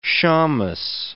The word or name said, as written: shamus